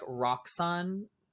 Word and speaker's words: Roxxon: You're basically, like, Roxxon Corp. Is that how you say it? – Oh, yeah, yeah, yeah.